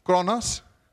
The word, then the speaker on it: chronos: The one is chronos. Do I pronounce that right? Chronos. Okay, you know it.